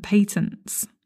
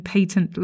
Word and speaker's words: patent: A quick note on pronunciation. Patent is the English pronunciation. Patent is the American pronunciation. However, I have to say, quite often in England, we kind of use them interchangeably. So my advice to you is whichever pronunciation you prefer, use that one. Patent is the English pronunciation. Patent is the US pronunciation.